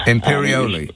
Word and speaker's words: Imperioli: Imperioli, by Michael Imperioli.